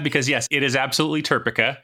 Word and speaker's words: TERPECA: So to make sure that we have a pronunciation that works in all dialects, it is definitely TERPECA. – That's how I say it. So I agree that is indeed the correct pronunciation.